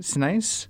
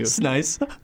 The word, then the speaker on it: Sinise: I really wanted to have Gary Sinise as the end game there. – Okay. Wait. – Sinise. Thank you. – Sinise.